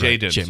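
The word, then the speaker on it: Jayden: Jalen. Oh God! – Hold it now. Jaden. – You're Jaden. Right. My bad.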